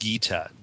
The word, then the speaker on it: Githa: And in this case, the play is especially significant because it was written by a woman named, I suppose it's pronounced Gita, G-I-T-H-A,